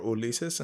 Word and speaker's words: Ulises: I chose Uni as the name that I go by, not because I do not like my given name, which is Ulysses or Ulises in Spanish, because that's technically how it's pronounced.